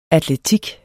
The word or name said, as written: atletik